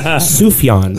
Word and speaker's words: Sufjan: Did I say that right? Sufjan? – Sufjan.